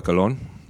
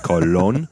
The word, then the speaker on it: Colón: He kind of details the Americas before and then right after Cristóbal Colón, which is his real name, not Columbus. – Yeah. – Colón. Why can't Americans say that? Colón. – Colón.